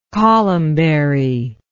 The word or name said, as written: columbary